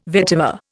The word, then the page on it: vetiver: Indian